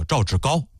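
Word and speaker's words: 赵志皋: 当时这个王家平呢就提议补充两位内阁新人，一个叫张卫，一个叫赵志高。高字啊上边一个白，下边一个本，有的念成赵志稿，其实这个字呢有高和豪这两个读音，他不念稿啊。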